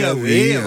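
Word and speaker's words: Eawy: Je ne sais pas si je le prononce bien. – Evi, Evi, Aoui.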